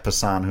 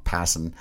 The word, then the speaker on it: Passan: and I believe it was Jeff Passan who wrote this, I always say his name wrong, Passan,